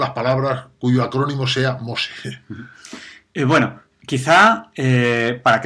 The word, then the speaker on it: MOSE: Este nombre bíblico, Moisés, que, como sabes significa salvado de las aguas, en italiano se dice Mose y es el acrónimo de Módulo Experimental Electromecánico.